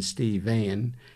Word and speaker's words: Stephen: Stephen, which was the name he introduced himself with to me, pronounced with a final A, as in Steve-Van,